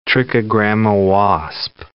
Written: trichogramma wasp

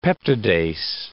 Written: peptidase

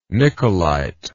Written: niccolite

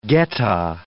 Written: geta

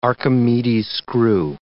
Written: Archimedes' screw

